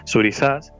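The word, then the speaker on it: Thurisaz: Bueno, el deletreado sería Thurizaz, perdón, S-A-Z, Thurizaz, ¿sí? – Que en español neutro se pronunciaría Thurizaz, pero como yo no pronuncio la Z.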